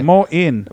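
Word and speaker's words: Moein: So, it's like boing, Moain. Right? – It's like those things. Moain. – No, yeah. – Oh, legit. – Yeah. – Okay. – Moin.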